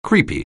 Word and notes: creepy: anglais

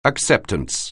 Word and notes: acceptance: anglais

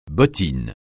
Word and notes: bottine: francais

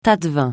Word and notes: tâte-vin: francais

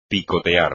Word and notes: picotear: espagnol

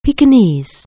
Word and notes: pekinese: anglais

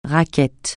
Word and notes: raquette: francais

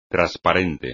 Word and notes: trasparente: espagnol

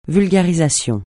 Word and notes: vulgarisation: francais

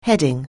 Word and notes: heading: anglais

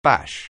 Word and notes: bash: anglais

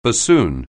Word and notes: bassoon: anglais

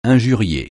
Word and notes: injurier: francais

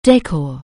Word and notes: decor: anglais

